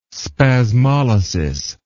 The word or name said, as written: spasmolysis